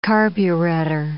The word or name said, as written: carburettor